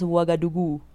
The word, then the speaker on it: Ouagadougou: Burkina Fasos huvudstad heter Ouagadougou. Sa jag det rätt? – Jag tror att det ska uttalas Ouagadougou.